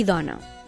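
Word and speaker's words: dóna: I en canvi, el verb dona es pronuncia amb O tancada i porta accent diacrític, que és tancat.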